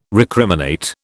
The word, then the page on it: recriminate: British English